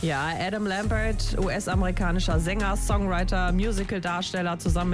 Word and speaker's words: Adam Lambert: Adam Lambert. – Du hast Adam Lambert.